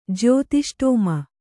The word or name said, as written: ಜ್ಯೋತಿಷ್ಟೋಮ